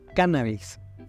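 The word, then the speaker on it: cánnabis: Sin embargo, durante las últimas décadas se ha introducido en nuestra lengua el término cannabis, con el acento en la primera A, tomado a través del inglés de la denominación científica latina y que ha ido desplazando a las anteriores palabras.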